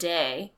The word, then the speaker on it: Daewi: I don't like saying the best friend role because they pronounce it Day, but it's spelled Daewi.